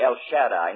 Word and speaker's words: Shaddai: Don't forget, you say El Shaddai, not Shaddai. And Adonai, and so on. When you have an A-I together, it's pronounced as I.